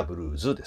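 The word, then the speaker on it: blues: で、英語で発音するときはブルーズですね。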